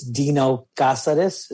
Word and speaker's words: Cazares: It's Dino Cazares is the correct way to pronounce my word.